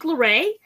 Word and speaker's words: Lore: If you're not following us on Instagram, at the startup with Monique Loray, L-O-R-E is how you spell it.